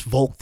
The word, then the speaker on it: Volk: In Germany, in Nazi Germany, the notion was the Volk, V-O-L-K, which in German, the V's are usually pronounced as the way we pronounce F's. So the Volk, right?